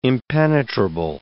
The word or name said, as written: impenetrable